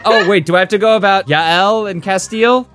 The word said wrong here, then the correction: Jael: I believe it's pronounced Jael. – Jael? Okay.